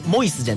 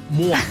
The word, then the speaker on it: MOIS: パンとお菓子の店、ブーランジェリー& パティスリーモアという MOIS と書いてモイスじゃなくてモア、はい、山陽電車尾上の松駅のロータリー角にオープンするということですから、うん、駅降りてすぐということですよね。